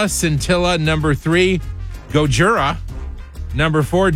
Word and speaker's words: Scintilla: S-C-I-N. – I guess it just rhymes with Priscilla, Scintilla.